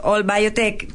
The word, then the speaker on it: ALL Biotech: Efectivamente, bueno, All Biotech, en realidad la sigla significa América Latina, líderes biotech, y además la idea es que es como un Spanglish, All All Biotech, porque somos todos los de Latinoamérica en la biotecnología.